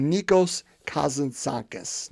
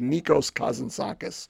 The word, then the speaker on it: Nikos Kazantzakis: And so, to use a second example, I want to use a story, a parable by Nikos Kazantzakis. Now, yes, I said that correctly. He's a Greek, Nikos Kazantzakis.